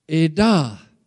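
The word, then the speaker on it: Edah (עדה): The next Hebrew word I want to give you is E-D-A-H, is pronounced Edah. You can do that. Edah. Practice with your neighbor.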